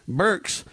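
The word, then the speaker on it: Birx: Burks are the two main doctors in this thing.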